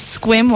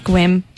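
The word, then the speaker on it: Sequim: Faith says Squim, Washington. It's like, it looks like sequin, but with an M, and it's pronounced Squim. Squim, Washington.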